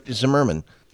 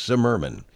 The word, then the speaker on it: Zimmerman: It's Zimmerman. – The name looks like it's spelled Zimmerman. – I know, and a lot of people think it's Zimmerman. It's Zimmerman.